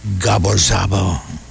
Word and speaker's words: Gabor Szabo: and Gabor Szabo.